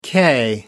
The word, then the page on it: k: American English